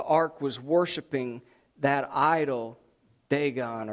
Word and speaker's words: Dagon: It looks like Dagon, but it's actually pronounced Dagon.